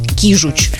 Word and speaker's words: кижуч: Везде ударение на первый слог. Кета, Сибас, Кижуч.